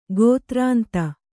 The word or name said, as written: ಗೋತ್ರಾಂತ